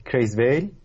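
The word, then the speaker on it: Creswell: برگردیم به این انتقادی که وارد میشه به کریزویل. درست گفتم یا؟ – بله بله بله.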